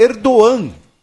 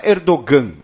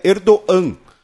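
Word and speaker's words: Erdoğan: Segundo o Enio, o G com circunflexo, em turco, ele tem uma pronúncia muda. Então, o nome do líder turco é Erdogan. Não é Erdogan, é Erdogan.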